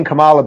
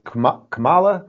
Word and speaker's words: Kamala: I keep saying Kamala, but it's Kamala, whatever.